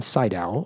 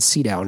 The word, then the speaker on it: Sydow: I'm going to say Max von Sydow, although I've heard it pronounced Max von Sydow now also.